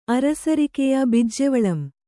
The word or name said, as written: ಅರಸರಿಕೆಯ ಬಿಜ್ಜೆವಳಂ